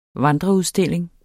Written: vandreudstilling

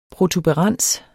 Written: protuberans

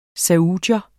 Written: saudier

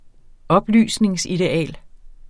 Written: oplysningsideal

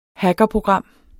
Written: hackerprogram

